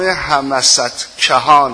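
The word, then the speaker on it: همستگان: خود این فرشته مهر داور دست این بابا رو میگرفت، میبردش در یک جایی به نام همست کهان. حالا من نمیدونم واقعا دارم درست تلفظ بکنم یا نه، به ده خدا هم که رجوع کردم همین تلفظ بود، همست کهان.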